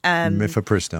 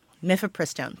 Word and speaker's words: Mifepristone: News item number two is the company that makes the abortion pill, which I always mispronounce, but Mifepristone? – Mifepristone. – But Mifepristone,